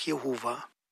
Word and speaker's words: Jahwe: Diejenigen, die wie im hebräischen Text den Eigennamen Gottes von den Titeln Gott und Herr unterscheiden, fügen traditionell die Vokale A und E oder auch E, O und A hinzu. Und so kommt man dann zu der Aussprache Jahwe oder Jahwe oder auch Jehova.